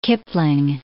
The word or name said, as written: Kipling